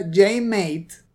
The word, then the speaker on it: JM8: Este capítulo en especial lo hizo un youtuber llamado JM8, o como él mismo lo pronuncia, JMate, es un profesor de level design de la universidad en Londres.